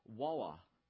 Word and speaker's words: war: And if you really get down and dirty with a deeply Southern Charlestonian, they will pronounce war with two syllables, walla, the walla of Northern Aggression.